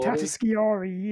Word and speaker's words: Tatasciore: Him and Fred, uh, I can never pronounce it. Tatagioscori. – Oh, Tatasciore, yeah.